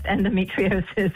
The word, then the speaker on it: endometriosis: You pronounced endometriosis perfectly.